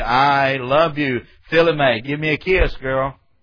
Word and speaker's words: philema: It's P-H-I-L-E-M-A, philema. Philema.